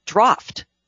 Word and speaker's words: draught: There's a pub that opened up recently, and it is spelled the English way, D-R-A-U-G-H-T. – Oh, yes. – So I went to your site, and it's pronounced draft. – Mm-hmm. Yeah. – But I think draft is probably how Americans would pronounce it.